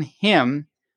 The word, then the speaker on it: hymn: And this caption is, N is for naughty children who will sing a solemn hymn when autumn comes to an end. – Okay. – Spots, like him and Autumn have silent N's.